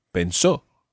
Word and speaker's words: pensó: Okay, so in the present tense, we've got pienso, displaying the radical change, and in the preterite, we've got pensó, not displaying the radical change, because the stressed syllable isn't the syllable that's going to change.